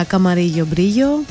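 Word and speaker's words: Camarillo Brillo: Το Καμαρίλο Μπρίλο είναι αυτό, ή αλλιώς με ισπανική προφορά Καμαρίγιο Μπρίγιο.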